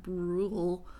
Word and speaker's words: Brule: Brule. I don't know how else you pronounce that. Brule. I mean, it's the word rule with a B in front of it.